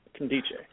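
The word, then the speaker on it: Nkemdiche: I think the N is silent. I think it is Ndiche. – Ndiche.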